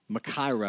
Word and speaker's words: Machaira: Well, the Greek word Machaira, I believe is how it's pronounced, M-A-C-H-A-I-R-A, is a large knife.